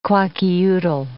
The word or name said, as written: Kwakiutl